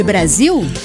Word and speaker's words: Brasil: Você sabe por que se escreve com S, apesar do som de Z, ZIL, de Brasil?